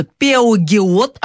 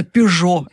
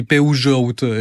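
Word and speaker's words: Peugeot: По поводу русского языка, сложный язык, нормально по-французски пишутся «Пеугиот», а читается «Пежо». Это какой язык еще сложный? – Ну, это фамилия, да-да-да. Я помню, да, даже по-испански они «Пеужоут» скажут, да, «Пежо».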